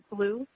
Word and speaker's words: Blew: Catherine, K-A-T-H-R-Y-N. And the last name is Blue, and it's spelled B-L-E-W.